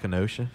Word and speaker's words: Kenosha: Is that how you say it? I haven't listened to the news enough to know the proper way of saying it. – We call it Kenosha.